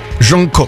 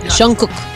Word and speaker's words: Jungkook: Jungkook. Mm-hmm. Am I saying it correctly? – Yes. – Jungkook.